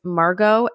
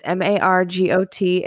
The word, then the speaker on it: Margot: My handle is Margot Explains It All. My name has a silent T at the end. It's Margot Explains It All on TikTok and Instagram.